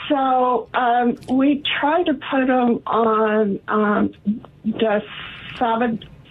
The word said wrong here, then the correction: Dasatinib: Is that pronounced wrong? – Dasatinib. Yes. Yeah.